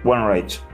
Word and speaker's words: Wainraich: ¿Y cómo es? Wenreich. Ok. Algunos dirían que es Renrack. no sé de dónde sacaste estas versiones, este, pero está bien, es válido. Puede ser. Cada uno lo pronuncia como puede.